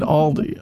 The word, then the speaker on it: Aldi: That's how you say it, Aldi.